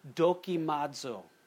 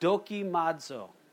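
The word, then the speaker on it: dokimazo (δοκιμάζω): In the Greek, it's the word dokimazo. Say that, dokimazo. Sounds like Japanese, you know, some ninjas say, dokimazo! Okay, just remember it like that, dokimazo.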